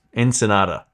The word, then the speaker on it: Ensenada: Ensenada.